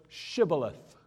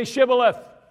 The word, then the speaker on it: Shibboleth: They selected a password that would be difficult to pronounce because it contained a consonant which was not in the Ephraimite dialect. The word was Shibboleth.